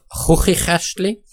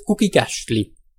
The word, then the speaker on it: Chuchichäschtli: Er wird also weiter hinten und bisweilen auch länger ausgesprochen als in der Standardsprache. Dadurch wird aus einem Schweizer Chuchichäschtli mit der Aussprache eines Ausländers oft ein ulkig klingendes Guggigäschtli.